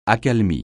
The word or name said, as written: accalmie